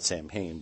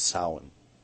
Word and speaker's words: Samhain: A lot of people call it Samhain, but the actual pronunciation is Samhain.